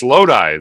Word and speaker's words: Lodi: No, it's Lodi. That's Lodi. It's the town in New Jersey. – Lodi. It's Lodi. Pronounced Lodi. – But it's the town in New Jersey. It's spelled like D-Lo, but Lodi.